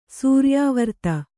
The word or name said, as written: ಸೂರ್ಯಾವರ್ತ